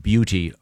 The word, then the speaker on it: Buda: It's spelled B-U-D-A, and I looked it up. It is pronounced like beauty.